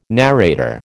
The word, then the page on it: narrator: American English